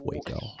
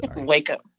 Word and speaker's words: Waco: Am I pronouncing that right? Sorry, it's Waco. – Waco. Yes, Waco, Texas.